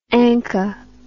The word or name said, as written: anka